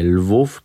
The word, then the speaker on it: Lwów: Mój następny przystanek dorównywał wojenną sławą Przemyślowi, bo mało znany Lwów to polska wersja starego, dobrego Lembergu. Jeżeli Przemyśl wymawia się jak kichnięcie, to żeby oddać autentyczne brzmienie słowa Lwów, musicie naśladować szczeknięcie ujadającego głębokim basem mastifa.